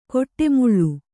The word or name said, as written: ಕೊಟ್ಟೆಮುಳ್ಳು